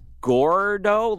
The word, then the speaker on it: Gordo: Is it Gordo? Like you're gorging yourself? – Yeah. – You're fat? – Well, G-O-R-D-O, El Gordo, the fat one.